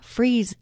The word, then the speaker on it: Fries: This is the Sue Freeze Show. Sue Freeze, spelled like fries, one word, .com.